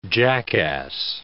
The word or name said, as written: jackass